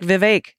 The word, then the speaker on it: Vivek: Vivek is pronounced like Vivek. It rhymes with mistake.